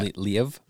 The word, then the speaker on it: Lviv: From the capital of Kyiv to Lviv? I don't know how to... I'm butchering these names, but... – Kyiv? – Yeah. – Lviv? – Yep.